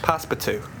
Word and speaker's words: Passepartout: You don't pronounce the T on the end. – Passepartout.